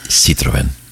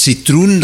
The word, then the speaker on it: Citroën: Maar het woord Citroën, dat konden de Fransen niet uitspreken. En daarom hebben ze Citroën, en ze hebben op de E dus maar gewoon twee puntjes gezet.